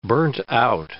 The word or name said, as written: burnt-out